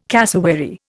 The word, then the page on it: cassowary: Indian